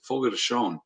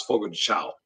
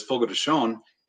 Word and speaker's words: Fogo de Chão: And Hobbs told me how to say this correctly. Fogo de Chão. I always thought it was Fogo de Chão. – That's what I thought. – A lot of people call it that, but it's Fogo de Chão.